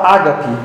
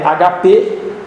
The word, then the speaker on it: agape: E esse amor, que não é o amor fraternal, é o amor que a gente erroneamente fala ágape. É uma palavra grega, ela não é uma palavra proparoxítona que tem que ser acentuada como ágape. Ela é agape ou agape, dependendo da pronúncia. Vamos falar agape.